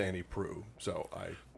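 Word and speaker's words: Proulx: It's not Prulx. It's not Prush. – No. – It's Prue.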